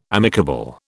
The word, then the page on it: amicable: British English